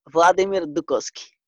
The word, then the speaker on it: Vladimir Dukoski: Vladimir Dukoski.